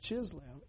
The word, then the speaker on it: Chislev: And in the month Chislev, which is the Greek name for the month we're in right now, which is Kislev, pronounced Chislev in the Greek, they go and take that temple over and re-cleanse it.